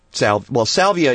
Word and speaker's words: Salvia: That's right. Salvia is how you say it? – Salvia is, yeah, is the general name of the plant, sort of the common name and the genus.